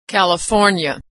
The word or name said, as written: California